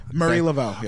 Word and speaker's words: Marie Laveau: Marie Laveau, yeah.